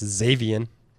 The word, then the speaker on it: Xavien: Howard Z- Z- Zaverin. Howard? Did I say that right? I think it's Xavien. Zay, that's what I said.